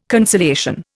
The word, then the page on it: conciliation: Indian